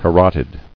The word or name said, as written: Carotidal